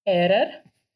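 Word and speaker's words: Hairer: Это профессор а, сэр Мартин Эрер, I think так произносится его имя, но там «ха» стоит в начале, но кажется, оно такое глухое.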